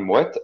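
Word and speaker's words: Moët: Der, den man Moe ausspricht. Und wie spricht also man dann richtig ausspricht? – Den spricht man Moet aus.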